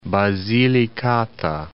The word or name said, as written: Basilicata